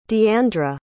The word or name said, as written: deandra